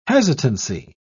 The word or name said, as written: hesitancy